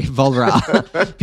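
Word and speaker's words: Walras: I was just reading Walrus and Marshall this past week, and they're like, Valra.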